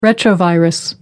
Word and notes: retrovirus: American English

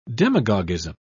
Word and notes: demagogism: British English

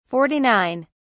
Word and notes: 49: British English

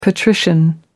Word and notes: patrician: American English

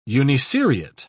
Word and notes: uniseriate: British English

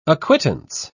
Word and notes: acquittance: British English

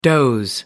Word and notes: doze: American English

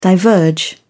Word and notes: diverge: British English

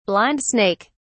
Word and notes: blind snake: British English

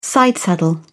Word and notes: sidesaddle: British English

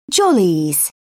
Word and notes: jollies: British English

